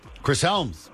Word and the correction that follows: Hemsworth: Chris Hemsworth. – Hemsworth, but I'll take it. – Hemsworth. Chris, yes. There's an L in there, isn't there? It's just mispronounced. Is there an L? – Nope.